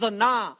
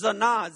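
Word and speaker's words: zana (זנה): It's pronounced zana. I didn't put it up there, but it's pronounced zana. ZA/NAW.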